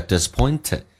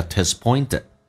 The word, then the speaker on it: disappointed: Alright, feeling disappointed 这个单词你可以不浊化，就是 T 还是发成 T 叫 disappointed，也可以发成 T 发成 D 叫 disappointed OK，最后一个 D 不发音，这叫略音。